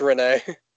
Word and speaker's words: Rennes: It's Rennes. Rennes? – Like Rennes. – Rennes. Stade Rennes. – You say the S? – Yeah, well. – No, it's a silent R. – Oh, yeah, it's a silent S.